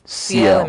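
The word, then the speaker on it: Ciel: What is CL? What does it even mean? Is that even how it's pronounced? – Yes. – CL immigration. That's right. That's right.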